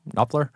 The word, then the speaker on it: Knopfler: Knopfler.